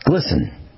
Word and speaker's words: GLSEN: And so the GLSEN, it's pronounced glisten, kind of like shimmering.